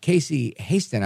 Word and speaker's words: Casey Hasten: Casey Hasten, I hope I'm saying that right.